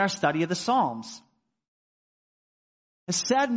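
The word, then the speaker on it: hesed (חסד): And I'm not going to be able to pronounce it properly, but it's pronounced hesed in English by this guy, right? There's some guttural sound there that I won't even try for you there. All right, hesed, H-E-S-E-D.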